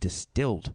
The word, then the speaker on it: DSTLD: and it's a jean sponsor, and it's Distilled, spelled D S T L D, all big letters there, pronounced Distilled.